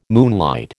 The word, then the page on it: moonlight: American English